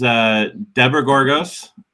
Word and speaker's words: Debra Gorgos: Well, this is, you know, our guest this morning is Debra Gorgos.